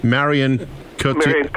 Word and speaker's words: Cotillard: and tell me how to pronounce Marion Cotillard. – Cotillard from 2 Days, 1 Night.